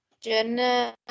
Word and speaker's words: Junna: Is it Juna? Or there's two N's in that. J-U-N-N-A. And... – Juna, yeah.